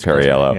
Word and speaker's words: Perriello: I can never pronounce his name. It is Perriello, yeah.